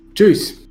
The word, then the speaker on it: juice: Juice. Beleza? Essa é, um, é uma, mispronun uma mispronunciation famosa, né? Esse i a gente não soa ele, né? Ele não tem som, a gente não pronuncia ele.